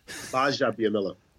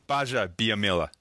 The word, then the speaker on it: Gbajabiamila: Is that how you pronounce it? – Gbajabiamila. – Gbajabiamila. – Mm-hmm. – Okay, we're here today with Akbar Gbajabiamila, an amazing guy. – Don't look at the G.